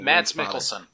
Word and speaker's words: Mads Mikkelsen: Mads Mikkelsen.